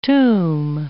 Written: toom

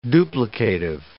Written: duplicative

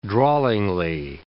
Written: drawlingly

